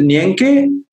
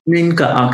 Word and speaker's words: Nienke: So my name is pronounced as Ninka. – Ninka, okay. All right, okay. – But it sounds like if you read it, a lot of people would say Nyenke.